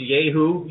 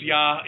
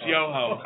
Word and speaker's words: Yoho: Isn't that pronounced Yeho? – No, it's Yoho. It's Yoho.